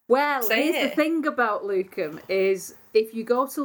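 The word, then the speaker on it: lucem: Now, I have a friend who's a retired Latin teacher who says it's got a hard C, it's Luchem.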